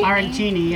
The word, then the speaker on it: arancini: Arancini? – Arancini, yeah.